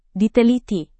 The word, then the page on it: diteliti: Indonesian